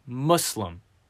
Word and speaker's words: Muslim: It is, and say it with me here, real slow and soft like, Muslim. It is not Muslim. It is Muslim.